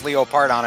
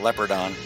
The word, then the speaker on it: Leopardon: What you guys assured me is Leo Pardon and not Leopardon. – Yeah, it actually is pronounced Leo Pardon.